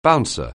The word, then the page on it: bouncer: anglais